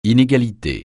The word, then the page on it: inégalité: francais